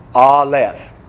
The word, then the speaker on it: Aleph: Now, the first letter of the Hebrew alphabet is pronounced Aleph.